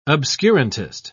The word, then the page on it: obscurantist: British English